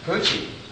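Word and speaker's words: Pucci: Poochie. I'm glad you pronounced that right. You know, if you say it wrong, you get a room full of pissed off women.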